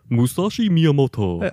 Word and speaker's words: Musashi Miyamoto: I don't think he pronounced that correctly. Try it again. – Musashi Miyamoto. – I think that's right. That sounds more right.